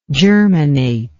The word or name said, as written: germinator